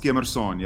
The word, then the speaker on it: Skemerson: And is it pronounced Skimmerson in Afrikaan? – Yeah, that's very well pronounced. Skimmerson, yeah.